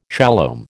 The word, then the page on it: shalom: American English